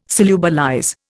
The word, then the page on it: solubilize: Indian